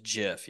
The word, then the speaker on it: GIF: Or I believe the correct pronunciation is JIF.